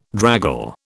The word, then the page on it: draggle: British English